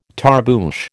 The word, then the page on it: tarboosh: American English